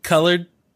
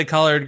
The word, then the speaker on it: collard: Colored was the way that it was said. I understand that it's spelt collard, but I will say collard greens for people's ease of listening.